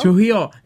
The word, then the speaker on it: Trujillo: I don't say his name right. I'm not good at Spanish. Tom Trujillo? Tom Trujillo? – Trujillo. – That's it.